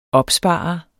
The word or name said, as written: opspare